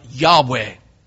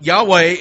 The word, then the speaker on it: Yahweh: And it may have been pronounced something like Yahweh.